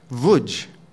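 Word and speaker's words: Łódź: Well, I understand it's pronounced Lutosławski, which is, do you know how the name of the town, L-O-D-Z, is pronounced? Wodz.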